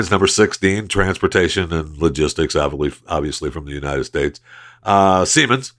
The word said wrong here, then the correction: Siemens: Siemens. – That's what I thought. That's what I said. – Siemens.